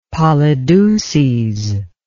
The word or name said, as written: polydeuces